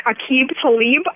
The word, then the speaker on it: Aqib Talib: Denver Broncos cornerback Aqib Talib.